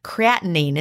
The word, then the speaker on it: creatinine: We've got ammonia, urea, creatinine. Is that, I always have to, is that creatinine. – Right? Creatinine. Creatinine. See, I think I always say it wrong.